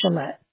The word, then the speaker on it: Chellette: My name's Shalette. – How do you spell that? – C-H-E-L-L-E-T-T-E.